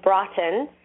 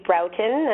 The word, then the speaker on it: Broughton: Actually, yeah, the name is English. And so the proper pronunciation is Broughton. – Broughton. – But I say Broughton, and that's...